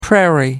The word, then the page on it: prairie: American English